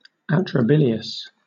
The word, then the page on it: atrabilious: British English